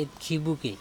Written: itkhibuke

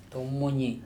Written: Tomoni

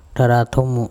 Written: Tharathomo

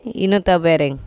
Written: inotabEreng

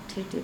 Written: THeTHe

